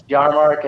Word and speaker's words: Yahrmarkt: My last name is terribly hard to pronounce and spell, but it's Yahrmarkt.